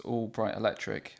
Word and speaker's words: All Bright Electric: I mean, they did when they toured All Bright Electric. I hope I'm saying that right because I've said it twice tonight. – No, I think you're right.